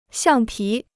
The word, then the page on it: 橡皮: xiàng pí